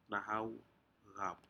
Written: nahau eap

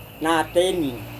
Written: nateni

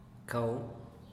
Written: cau

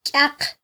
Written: kyaq

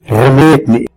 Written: rremedmi

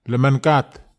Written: lemankat

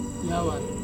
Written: yawar

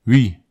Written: wi

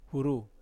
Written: huru

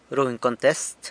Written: runy contest

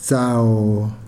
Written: tsao

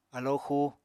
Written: alóo xuu

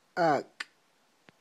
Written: ak'

